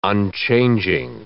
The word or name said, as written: unchanging